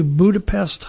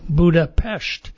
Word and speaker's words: Budapest: to Budapest, Hungary, or to pronounce it correctly, Budapest.